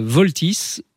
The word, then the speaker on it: Vaultys: Alors voilà, pour résoudre ce problème de piratage finalement, Voltys, ça s'écrit VAULTYS, je l'épelle parce que c'est important, voilà.